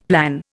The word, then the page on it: plan: Indian